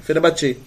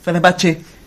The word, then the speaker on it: Fenerbahçe: Foi pro Fenerbahçe. É assim que fala. Eu conheci uma turca lá na França. Que eu perguntei pra ela como se pronunciava. Fenerbahçe.